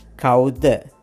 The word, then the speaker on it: කවුද: Let me spell it. K-A-U-D-A. කවුද? But D-A sounds more like T-H-E sound. The sound. කවුද?